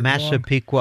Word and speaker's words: Massapequa: He's someone who, as a young man, was living in Massapequa, Long Island. I should pronounce that better. I'm probably getting it Massapequa.